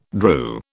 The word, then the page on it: drew: British English